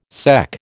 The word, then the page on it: sec: American English